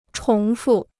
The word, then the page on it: 重复: chóng fù